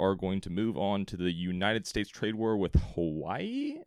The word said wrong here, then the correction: Huawei: Am I pronouncing that right, Connor? – No, Huawei. – Huawei, that is right.